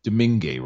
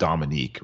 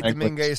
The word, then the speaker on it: Domingue: So between DeSmith and it's Domingue, right? That's how you pronounce the other goalie's name. It's not Dominique, right? It's Domingue. – You could say Domingue. Some people say Dominique.